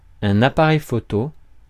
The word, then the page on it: appareil photo: /a.pa.ʁɛj fɔ.to/